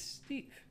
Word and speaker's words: Steaf: No, it's, it's Steve. – Oh, right. Yes, Cinemy, of course. – Yes, it's spelled um, S T E A F, Steve. like leaf, but with an S.